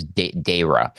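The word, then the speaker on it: Dheira: That's D-H-E-I-R-A, sometimes spelled with D-H-A-Y-R-A, but I'm pretty sure it's Deira.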